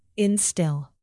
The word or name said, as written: instill